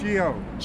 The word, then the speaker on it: Geo: My name is Gio. – Gio. Like, how do you spell that? – G-E-O.